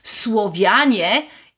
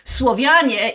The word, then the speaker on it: Słowianie: I wish I could pronounce these names in their native languages as I can pronounce Słowianie in my native language. Słowianie is a name of Slavs in Polish.